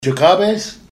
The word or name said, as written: jkaabes